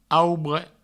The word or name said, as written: aubre